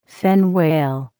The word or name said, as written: fin whale